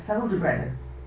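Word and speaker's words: Ffald-y-Brenin: And it's taken me years to say that right, Feldebrennen.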